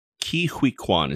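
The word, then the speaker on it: Ke Huy Quan: Ke-Huy Quan is how he pronounces it, but yeah.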